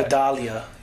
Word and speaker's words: Vidalia: I don't know if there's an English pronunciation to that. – Vidalia. You got to just say it like that.